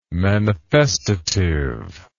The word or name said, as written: manifestative